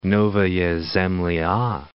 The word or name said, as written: Novaya Zemlya